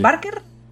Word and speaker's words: Troy Baker: Eh, Troy, ¿Es Troy? Baker? ¿Lo he dicho bien? – sí, sí. sí. ¿Barker? Troy Baker. No sé si Troy Baker. – eso es.